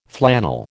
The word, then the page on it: flannel: American English